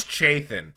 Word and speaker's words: Chthon: How do you say this? Chthon? – I think it's Chthon.